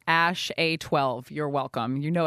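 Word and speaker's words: Æ: It's just X, the letter X. And then the AE is like pronounced Ash. And then A12 is my contribution.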